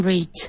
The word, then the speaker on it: rich: I'm still trying to become rich. – Very good. Ya no soy pobre, se ve. By the way, say rich with a very short vowel sound.